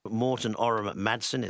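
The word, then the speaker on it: Morten Ørum Madsen: Morten Orum Madsen, and that's how you pronounce his name.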